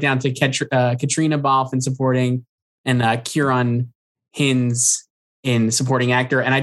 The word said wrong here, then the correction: Hinds: And he phonetically spelled it out, and it's Kieran, and then Hines rhymes with Bynes.